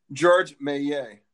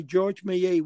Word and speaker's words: Georges Méliès: Georges Méliès. – Okay, Georges Méliès.